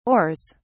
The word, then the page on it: oars: British English